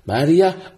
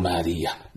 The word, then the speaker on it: Maria: Maria or Maria. Both pronunciations are correct. Meaning with a sakin on the ya or a tashdeed on the ya.